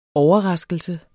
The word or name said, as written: OVERRASKELSEN